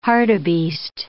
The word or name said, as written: hartebeest